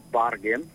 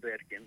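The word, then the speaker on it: Bergen: Μας έχουν πει ότι πρέπει να τη λέμε Μπάρκεν. – Με αλφα. – Και όχι Μπέρκεν. Με αλφα. Κάτι σαν αλφα. – Ναι. – Και όχι Μπέρκεν. Γιατί θέλουν να διατηρήσουν αυτό, αυτή είναι η επίσημη ονομασία.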